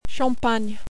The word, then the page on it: Champagne: French